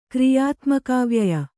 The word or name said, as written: ಕ್ರಿಯಾತ್ಮಕಾವ್ಯಯ